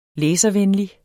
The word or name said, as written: læservenlig